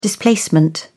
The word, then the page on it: displacement: British English